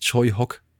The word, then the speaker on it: Tsui Hark: Den kennt ihr eher vielleicht geschrieben als Tsui Hark, aber er wird tatsächlich Choi Hock ausgesprochen.